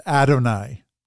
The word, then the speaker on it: Adonai: And interestingly, the plural form of this Hebrew word is Adonai. You might recognize it as Adonai, with this emphasis on the first syllable.